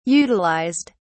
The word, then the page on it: utilized: British English